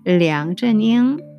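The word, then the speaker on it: 량전잉 (梁振英): 예, 이 둥젠화가 일기 그리고 도널드 어, 창 조금 전에 제가 설명드렸던 그분이 이 그리고 그 다음 분이 이 렁추닝 해가지고 발음이 좀 이상하긴 한데요. 량전잉 하는 분이요. 량전잉, 네, 렁짠 렁짜닝 뭐 이러면 이제 병음처럼 돼가지고 그 한자 병음은 정확하게 말씀드리면 량 량쩌닝이란 분이에요.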